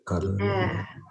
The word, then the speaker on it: Ada: I hope I'm pronouncing her name correctly. I think I am. – The first Ada Limon in Spanish. – Ada.